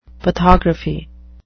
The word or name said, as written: pathography